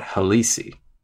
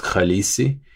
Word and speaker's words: Khaleesi: And so I should have just respelled it on my own and just said that the word is pronounced Khaleesi. I also could have rescued the stress. Even if it were pronounced that way, it should be stressed Khaleesi.